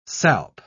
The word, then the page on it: salp: British English